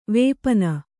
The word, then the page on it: ವಿಪಿನ: vipina